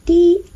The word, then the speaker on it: 掂: 在粤语里面它是一个闭唇音的一 im 掂，调整声调掂丁。粤语的拼音是的一摸，如果是英文就是 dim dim 低音，调整声调，低音。